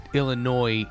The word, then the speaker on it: Illinois: Illinois is combating what's happening in Texas with that law, but and yeah, it's pronounced without the S.